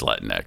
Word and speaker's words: Latinx: Yeah, well, and first of all, is that the proper pronunciation? Is it Latinx? – Is it like Kleenex or is it Latinx? – It's Latinx. – That's what I thought.